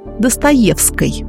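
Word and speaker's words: Достоевский: Кстати, фамилия знаменитого писателя в XIX веке произносилась так – «Достоевской».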